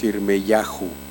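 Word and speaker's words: Yirmeyahu: Nosotros le decimos a Jeremías, Jeremías, porque es fácil pronunciarlo así en español, pero su nombre en realidad es Irmeyahu. Quiere pronunciar correctamente su nombre, es Irmeyahu.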